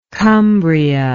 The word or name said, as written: cumbria